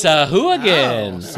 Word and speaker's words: Sahuagin: It's Sahuagin. All right. – Like hooligan. – So check out Sahuagin Warband available from WizKids.